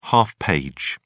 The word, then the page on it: half page: British English